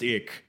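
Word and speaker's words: ich: And he's like, well, another thing is like, it's the German word for I, and that is pronounced ick.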